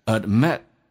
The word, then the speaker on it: admit: You gotta 就是 got to 的意思，同时呢，got to 就相当于 have to，你得 admit 这个单词呢，d 跟 m 两个辅音在一起，只用发后面一个，所以 d 可以去掉，气流阻塞一下。尾音的 t 也可以不发音，一个元音加一个辅音，辅音可以不发音，但气流阻塞就变成 admit。